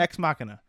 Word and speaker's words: Ex Machina: With the girl from, with the woman from Des Machina. How do you say that movie? – Ex Machina? – Ex Machina. The robot girl from Ex Machina.